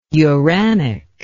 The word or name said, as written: uranic